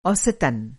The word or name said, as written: औसतन